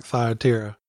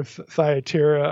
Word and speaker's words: Thyatira: If anything, I'm learning how to pronounce some of these words, like Thyatira. Is it Thyatira? which one is it? – I prefer Thyatira. I mean, you'll hear it pronounced both ways, you know.